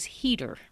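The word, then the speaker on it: Heatter: Her name's Maida, M-A-I-D-A. And her last name is Heater, rhymes with the word cheater. It's H-E-A-T-T-E-R.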